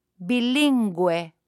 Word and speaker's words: bilingüe: If there are two little dots on top of the U, then the U is pronounced. Listen and repeat. – Pingüino. Bilingüe.